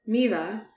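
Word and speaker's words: Mývatn: And then we found out Lake Mivatan is actually pronounced Miva because in Icelandic, M Y V A T N, the T N just guides which A sound you put.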